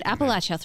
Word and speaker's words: Appalachia: Yeah, so that is Old Gods of Appalachia. – Great. So that's, and I pronounced it right there. – You did. Appalachia.